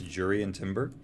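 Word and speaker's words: Jurriën Timber: Everyone in the UK obviously mispronounces him, but I hear it's Jurian Timber.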